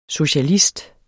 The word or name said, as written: socialist